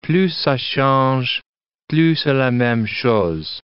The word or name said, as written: plus ca change, plus c'est la meme chose